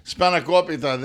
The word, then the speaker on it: spanakopita: Listeners, for the Spanokopitas. Spanakopita, this is awesome. – Don't make me tell you how to pronounce Greek if you can't pronounce it yourself. So these are spanakopita.